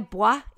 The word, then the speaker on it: boi: The reason I know that you have to pronounce B-O-I, boy, is because Dan and I's Rocket League team name when we play against bots is the Kissy Boys.